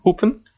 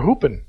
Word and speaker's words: Hupen: Hupen. – You're hooping.